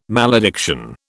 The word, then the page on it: malediction: British English